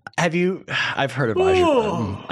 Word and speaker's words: Azerbaijan: So then we decided to download 2K21 Golf, do a creative player from, I think it's pronounced Azerbaijan. Azerbaijan, thank you.